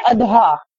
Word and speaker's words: اضحیٰ: ادھا تو اس کی جمع ہے ادھا اور اداہی, اسی طرح یوم الادھا, تو صحیح تلفظ کیا ہے ادھا, اور اس کے معنی ہے قربانی, اس کے معنی ہے جانور ذبح کرنا.